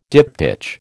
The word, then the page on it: diptych: American English